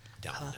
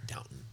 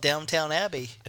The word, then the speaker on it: Downton: I wonder if they watch Downton Abbey as well. I think it goes together. – Downton Abbey. – The vicar? – Downton.